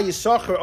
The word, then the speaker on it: יששכר: You know why Yisachar only has one, when you pronounce it, you only pronounce one shin, because one of them he gave away.